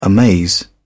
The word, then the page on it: amaze: British English